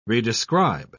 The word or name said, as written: redescribe